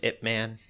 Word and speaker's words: Ip Man: I definitely thought it was Ip Man. – Yeah, that's the way it's pronounced.